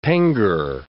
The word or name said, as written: pengo